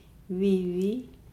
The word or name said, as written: vivi